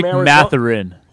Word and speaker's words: Mathurin: That rookie they got, how do you pronounce his name, Matherson? – Benedict Mathurin. – Mathurin.